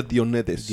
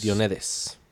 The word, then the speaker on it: Diomedes: La próxima la tarea son unas yeguas de Diomedes. A ver si lo dije bien. – Yeguas de Diomedes. – Diomedes.